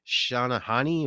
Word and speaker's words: Shehnai: I believe it's pronounced Shanahani or Shani. I don't know. Shehnai.